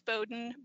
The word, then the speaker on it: Bowden: And my last name is Bowden, B-O-W-D-E-N. And I always tell people that's Bo, like what little girls wear in their hair, and Den, like where a bear lives.